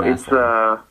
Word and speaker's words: Massillon: And then your dad, who coached our rec basketball team back in the day, he hails from, I'm going to mispronounce it, Massillon, Ohio, near Cannon? – Massillon, Ohio. Massillon.